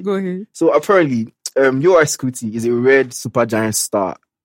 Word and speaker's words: UY Scuti: Is it U Y Scuti? – UY Scuti.